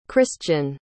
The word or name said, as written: christion